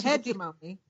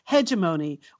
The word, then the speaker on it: hegemony: As a result, some of those smart people talk about hegemony, which is not the preferred pronunciation.